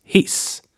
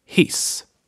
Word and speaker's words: hiss: So let's take the short I. It is similar to the sound in English words like sick and bliss. A Swedish word with this sound is hiss, which means elevator. Say after me, hiss.